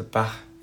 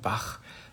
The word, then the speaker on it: Bach: And today I'm gonna try out something new, read from this wonderful book, The Bach Flower Remedies, to you. It's written by, well, I guess in German you would pronounce it Edward Bach, but in English probably you'd say Edward Bach.